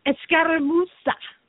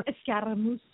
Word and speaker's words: Escaramuza: Escaramuza. – See, you do it so much better than me. So much better. – Escaramuza. Got to roll your R's.